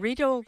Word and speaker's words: Riedel: And Riedel, which rhymes with needle, they have some great deals at HomeSense.